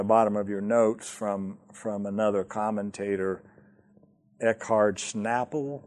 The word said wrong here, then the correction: Schnabel: I don't know how to pronounce that name. S-C-H-N-A-B-E-L. How do you pronounce that? Schnabel? Schnabel. Okay, thank you, Sandy.